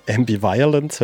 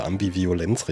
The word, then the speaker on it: Ambivalenz: Ambivalence höre ich auch oft. Aber ich meine, wie wir mal mit denen gespielt haben, haben die uns gesteckt, dass wirklich die deutsche Aussprache Ambivalenz richtig ist.